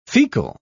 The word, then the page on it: fecal: British English